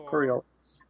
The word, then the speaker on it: Coriolis: Is it Coloris? Coloris RPG? – Coriolis. – What was it? – Coriolis. – Yeah, I think that's probably right. – Coriolis is what I'm going to go with.